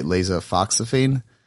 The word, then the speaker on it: lasofoxifene: Can you walk us through how did you come to that realization on that specific, I mean, am I pronouncing it right, lasofoxifene? – Very good. Very good. It usually takes, takes a few tries.